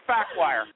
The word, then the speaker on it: Faquir: His name's Assad Fakwire, right? But it's spelled F-A-Q-U-I-R, right?